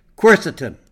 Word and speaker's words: quercetin: And our featured topic today is quercetin.